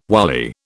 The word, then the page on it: wily: British English